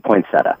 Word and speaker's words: poinsettia: So you're saying you got to pronounce the I? – I actually say poinsettia. I'm just saying. – You're just that was good. – You're just being a jackwagon. – No. But it's poinsettia.